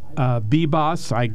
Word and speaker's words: Bibas: You know, the judge down in Philadelphia, this is Stefanos Bibas, B-I-B-A-S. I don't know how to pronounce his name. Sounds right.